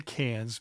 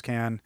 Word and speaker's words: Cannes: It's hard for me to say can. I always want to say cans because I'm American and it's spelt with an S on the end.